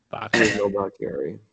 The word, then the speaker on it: Bakhtiari: Bakhtiari. – Bakhtiari.